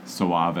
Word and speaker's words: Soave: Suave. S-O-A-V-E.